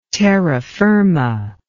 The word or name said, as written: terra firma